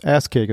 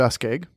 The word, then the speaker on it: Askaig: And it's that's how it's pronounced. Ass keg is A-S-K-A-I-G. It's an Islay scotch. – It's poured ass keg.